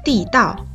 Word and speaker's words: 地道: 不过啊，地道这个词还有另外一种读音，“ 道” 字读作四声，地道。